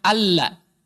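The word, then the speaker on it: alla (أَلَّا): Tulisan nanti kalau secara pelafalan dibacanya adalah alla.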